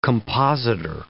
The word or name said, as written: compositor